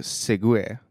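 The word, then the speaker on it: segue: Du vet, men, du vet eh, ju att det, det, det heter ju segway, det uttalas segway, men alltså det är S-E-G-U-E. -E.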